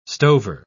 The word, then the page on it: stover: British English